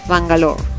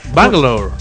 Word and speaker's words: Bangalore: Un grupo de ancianos ingleses deciden disfrutar de su jubilación en Bangalore, ¿no? Así se dice, a ver tú que todo lo pronuncias. – ¿Cómo? – Bangalore. – Bangalore.